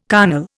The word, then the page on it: carnal: Indian